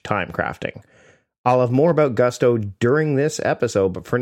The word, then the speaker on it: Gusto: What is Gusto? Well, it rhymes with musto or rusto, as in let's do it once more with Gusto.